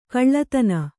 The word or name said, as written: ಕುಲ್ಲತನ